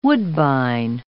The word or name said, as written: woodbine